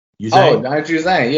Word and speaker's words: Juzang: Is it Juzang? – Juzang.